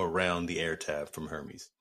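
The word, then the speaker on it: Hermès: Um, outside of and the- and, and and apparently the uh the, the H is silent. Hermes.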